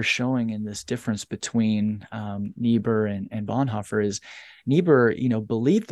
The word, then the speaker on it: Niebuhr: but it's Reinhold Niebuhr, I think. Is that how you say it? Okay. – Niebuhr. – Yeah, Niebuhr.